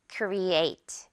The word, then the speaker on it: create: 이 중간에 있는 a에 강세가 있어요. 그래서 create. a 이 부분을 강하게 올려주면 돼요.